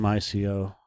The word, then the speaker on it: Ico: I think it's Team ICO. – They pronounce it Ico because the first game that they made for the PlayStation 2 was called Ico.